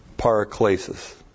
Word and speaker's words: paraklesis: By the way, that word in verse 16, God has given us eternal comfort or encouragement, is aionios paraklesis. Aionios, A-I long O-N-I-O-S. Aionios is the word eternal, means eternal. Eternal paraklesis. Looks like this in the Greek. P-A-R-A-K-L long E-S-I-S. Paraklesis.